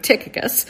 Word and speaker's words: Tychicus: On the other hand, the book ends with a mention of a fellow laborer with Paul named Tychicus, and you'll find quite a variety of pronunciations of his name. I'm sticking with Tychicus, where Paul specifically says that Tychicus will come to them and catch them up on how Paul is doing.